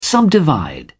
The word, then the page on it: subdivide: British English